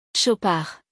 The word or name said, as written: Chopard